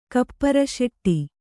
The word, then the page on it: ಕಪ್ಪುರಸೆಟ್ಟಿ: Kannada